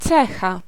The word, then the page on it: eigenschap: [ˈɛi.ɣənˌsxap]; /ˈɛi.ɣənˌsxɑp/